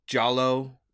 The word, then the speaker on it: giallo: I thought you pronounced that totally differently. – Giallo. – I did not think that was how you did it. – Yeah. Gialli is the... – It's with a G, right? – Yeah.